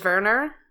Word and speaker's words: Wernher: Wernher. – Yeah. – You lived in Germany, right? – I lived in Switzerland. It's going to be something like that. It's going to be Werner.